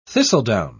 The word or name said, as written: thistledown